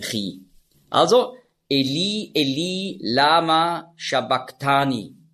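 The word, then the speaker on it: sabachthani: Und das CH im Griechischen, das ist ja ein Buchstabe, das Chi, das hat man ausgesprochen so wie ein C hinten im Gaumen. Also nicht Shabach, sondern Shabak.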